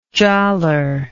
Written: jalor

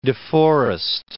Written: De Forest

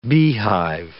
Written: beehive

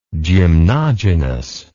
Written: gymnogynous

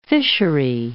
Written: fishery

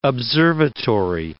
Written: observatory

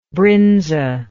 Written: brynza

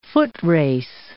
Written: footrace

